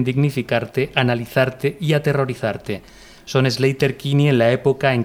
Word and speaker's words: Sleater: Que no se dice Slater, es Slater. – Slater.